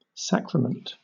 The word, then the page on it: sacrament: British English